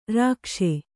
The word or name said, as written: ರೇಗಿಸು